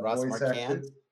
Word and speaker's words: Marquand: Marquardt or whatever that Ross Marquand.